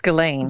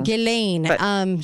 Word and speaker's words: Ghislaine: Is it Ghislaine or Ghislaine? I don't even know. – I think it's pronounced Ghislaine. – Ghislaine.